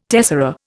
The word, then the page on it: tessera: Indian